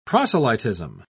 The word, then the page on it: proselytism: British English